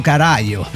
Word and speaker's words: Carallo: forma parte de la banda Tropa do Caralo. Tropa do Carallo, sí. Lo estoy pronunciando, es galego, disculpe.